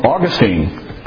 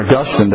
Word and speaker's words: Augustine: Well, Augustine, or Augustine, depending on how you want to pronounce that.